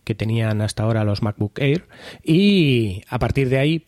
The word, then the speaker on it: MacBook Air: Entonces, la filtración se basa en que eh, Apple sacaría unos nuevos MacBook Air.